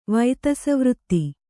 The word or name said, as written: ವೈತಸವೃತ್ತಿ